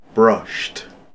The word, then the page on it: brushed: British English